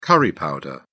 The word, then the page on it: curry powder: British English